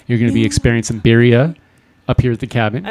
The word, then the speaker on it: birria: Birria. – Birria.